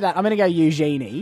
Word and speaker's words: Eugenie: Eugenie. – It's not Eugenie. – It is. – It's Eugenie. – It's a greeting that you'd say to a genie.